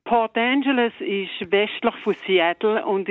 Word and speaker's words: Port Angeles: Von Port Angeles, habe ich es richtig gesagt? – Ja, das ist richtig.